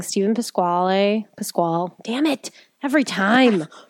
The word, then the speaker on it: Pasquale: It's Italian, and that's what it should be. Pasquale is how it should be pronounced. But Pasquale, there's an E on that.